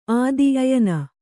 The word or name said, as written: ಆದಿ ಅಯನ